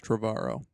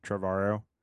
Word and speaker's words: Trevorrow: I think it's Trevorrow. – Trevorrow. But it's spelled like tomorrow.